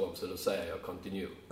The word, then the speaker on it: continue: Eh, men eh, då visste jag inte hur det uttalades. Så man sa continue. Mm. Eh, Välj continue. Mm. Och, man, och sen men så förstod man ju att det här det betyder fortsätt. – Mm. – och uttalas continue.